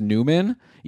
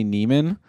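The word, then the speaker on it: Nymen: Okay, apparently his name is pronounced Newman, even though it's N-Y-M-E-N. I've always assumed Yanni Neiman, but I guess I'll have to believe that it's Newman. So he's just got called up, scored a goal in his first game against Montreal.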